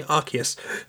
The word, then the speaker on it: Arceus: It turns out that's how you say it, by the way, Arceus.